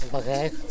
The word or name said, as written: bagegu